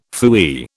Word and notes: phooey: British English

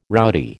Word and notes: rowdy: American English

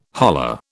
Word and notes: holler: British English

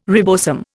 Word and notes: ribosome: Indian

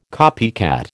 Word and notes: copycat: American English

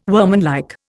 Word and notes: workmanlike: Indian

